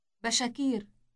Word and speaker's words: بشاكير: Here's the plural form for napkin. – Bashakir. Bashakir. – You simply insert an a sound after sha.